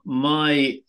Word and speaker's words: MyDawa: So tell me now, if I'm right, my pronunciation may be incorrect. You are now working for a company called MyDawa. Am I right? – Yes, exactly. MyDawa translates from Swahili to My Medicine.